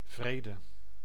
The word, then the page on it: paix: France; [pɛ]